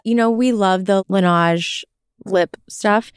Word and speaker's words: Laneige: It's Laneige, yeah.